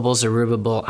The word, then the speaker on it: Zerubbabel: So even for the Jews coming back under Zerubbabel, or how do you pronounce it? – Zerubbabel.